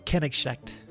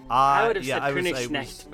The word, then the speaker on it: Königsknecht: It is pronounced Kenegschnecht. – Kenegschnecht. – Call him K-13. – Königsknecht, but that's because I speak German.